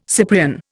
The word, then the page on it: Cyprian: Indian